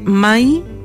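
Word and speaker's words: mãe: Entonces, Mai significa mamá en portugués. Es la pronunciación de mamá.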